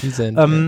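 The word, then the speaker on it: Wisente: In diesem Interview reden sie auch davon, dass sie bei Wissens ja, wie spricht man das richtig aus, diese lustigen rinderähnlichen Tiere, Wisens, ne? – Wisens.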